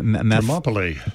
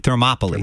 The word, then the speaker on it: Thermopylae: It's about the battle of, I can't pronounce it. – Thermopylae. – Thermopylae, there it is.